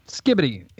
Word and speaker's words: Skibidi: Is it just Skibbity, or is it like Skibbity? It's by Little Big, and it's spelled Skibidi.